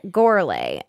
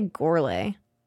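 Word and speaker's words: Gourlay: I always want to say Gorley. Gorley. – Gorley. – Gorley. – Like Ole.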